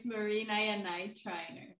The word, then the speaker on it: Marina Yanay-Triner: My name is Marina Yanay-Triner, and you are a somatic healer and trauma coach.